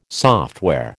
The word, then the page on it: software: American English